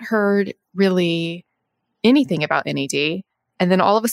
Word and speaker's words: NAD+: Okay, and now NAD, which is pronounced NAD with a silent plus.